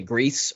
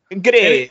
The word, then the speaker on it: Gris: Number five, I put as, I guess depends on how you pronounce it, Greece or Gris. – Gris? – Oh, G-R-I-S. – I've always said Gris, yeah. like the S is silent, but I don't know why.